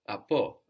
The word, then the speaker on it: από: Apo, with the accent on the end, apo.